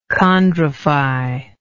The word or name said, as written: chondrify